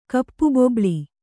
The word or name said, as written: ಕಪ್ಪುಬೊಬ್ಬುಳಿ